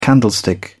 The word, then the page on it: candlestick: British English